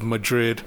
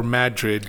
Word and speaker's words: Madrid: If you take that road, it takes you to a small town of Madrid or Madrid, depending how you want to pronounce it.